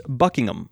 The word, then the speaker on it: Buckingham: And the Queen, she lives at Buckingham Palace, but it's not Buckingham, it's Buckingham. Buckingham. Kind of like Birmingham, Alabama.